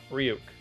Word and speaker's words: Ryuk: Ryuk? – Yep, Ryuk.